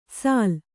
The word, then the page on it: ಸಾಲ್: Kannada